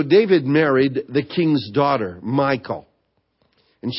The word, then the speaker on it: Michal: Remember Michal, some pronounce it Michal, Saul's daughter.